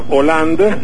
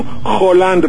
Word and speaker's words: Hollande: El hecho de que haya ganado el señor Hollande, nada de Hollande, señorita locutora. Bueno, es que no, hace ratito estaban friega y friega con Hollande, porque están acostumbrados a la pronunciación de Miami, no es Monsieur Hollande.